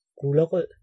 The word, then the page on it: gulerod: /ɡuləroːd/; [ˈɡ̊uləˌʁoːˀð]; [ˈɡ̊uləˌʁoðˀ]